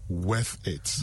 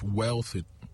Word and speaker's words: worth: So, I don't know how people find L in that, but look, it is W-O-R-T-H. Worth it. – Not wealth. – No. No, let's not go there. – So now I know how to say it. It's worth it, not wealth it.